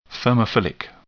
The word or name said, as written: thermophilic